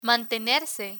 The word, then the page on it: mantenerse: spanish